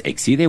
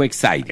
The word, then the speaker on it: Exide: Se depende como esto, se acuerda que ven unas baterías Exide o Exide. – Exide o Exide. – Como quiera. Hormuz u Hormuz. Dentro se escribe Exide, se pronuncia Exide.